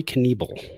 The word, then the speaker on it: Knebel: Corey Knebel. – Knebel. – Yeah. – Knebel starts. Knebel starts. And I'll tell you, I was very frustrated with Brandon Crawford, and and I'll tell you why. So, Knebel, as a reliever, he's got two pitches.